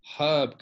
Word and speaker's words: herb: Our herb garden, not herb garden, if you're American, is doing phenomenally well. – Silent H down in the States? – Yeah. Herb. That's weird. Herb.